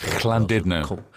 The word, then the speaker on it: Llandudno: They're all pretty much sold out, but Llandudno next Saturday. This ticket's left. – Llandudno.